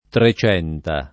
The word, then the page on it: Trecenta: lingua italiana